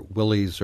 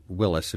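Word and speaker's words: Willys: So the couple's driving down the road in their Willies or Willis, and it can be pronounced either way, right? – Yes.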